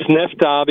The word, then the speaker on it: Nefta: NEFTA, am I saying that correctly? – Yes, it's NEFTA because the location of the movie is very near to NEFTA, who is a place at the border.